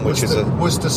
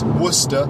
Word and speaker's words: Worcester: And Worcestershire is spelled Worcestershire, and it's pronounced not Worcestershire, but pronounced Worcestershire, and the capital, the main city in Worcestershire is Worcester, which is spelled Worcester, but it's pronounced Worcester.